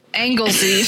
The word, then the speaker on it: Anglesey: It's spelled angle and then S-Y. So, yes, Anglesey. – Seems like Anglesey to me.